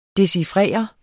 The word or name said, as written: DECHIFRERES